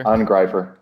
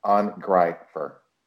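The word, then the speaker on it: Angreifer: Pronounce the E-I as a hard R, as a hard I. Angrifier.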